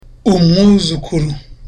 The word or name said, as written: umwuzukuru